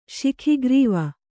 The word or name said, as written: ಶಿಖಿಗ್ರೀವ